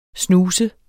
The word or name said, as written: snuse